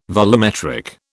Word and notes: volumetric: British English

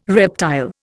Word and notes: reptile: Indian

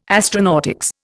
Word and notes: astronautics: Indian

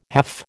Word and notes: half: American English

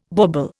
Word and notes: bauble: Indian

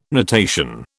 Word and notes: natation: British English

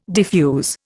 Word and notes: defuse: Indian

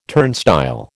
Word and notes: turnstile: American English

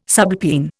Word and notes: subalpine: Indian

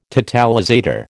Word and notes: totalizator: American English

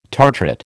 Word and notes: tartrate: American English

